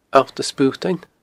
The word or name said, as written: Allt a Sputain